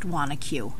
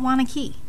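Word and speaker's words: Wanaque: It is pronounced Wanacue, not Wanakee.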